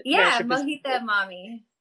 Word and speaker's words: Mohita Mami: Mohita Mami.